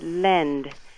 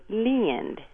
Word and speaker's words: lend: They won't always use the correct term, as in the word lend has a short E sound, but they hear it lend here in the South.